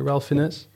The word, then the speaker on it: Ralph Fiennes: Ralph Fiennes? – Yeah.